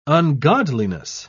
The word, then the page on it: ungodliness: British English